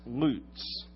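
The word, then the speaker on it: loots (לוץ): The word scoffer in the Hebrew is pronounced loots, L-O-O-T-S.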